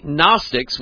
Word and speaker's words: Gnostics: And the Gnostics, with a G at the beginning, but the G is silent, or you can say it Gnostics,